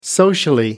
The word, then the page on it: socially: American English